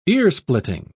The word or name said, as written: earsplitting